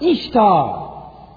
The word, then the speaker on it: Ishtar: The fish rolled on the sea, or out of the river Euphrates, onto the side of the river, and hatched out Ishtar, pronounced Ishtar, which becomes Easter.